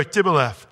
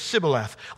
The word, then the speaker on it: Shibboleth: And if they would go, Tibboleth, you know, they would say it like that instead of with the strong sh, Ibboleth, you know. If they just said Shibboleth like that, they'd put him to death, you know.